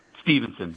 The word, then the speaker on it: Stevenson: When we come back, we're going to be on with Adam's, is it Stevenson or Stephenson? How do you say it? Stevenson? – Stevenson. Yep.